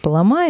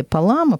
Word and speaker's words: Палама: И архиепископ Фессалоникийский Григорий Палама, или Палама, по-разному ставят ударение, он на нескольких соборах отстаивал православность вот этой практики исихазской.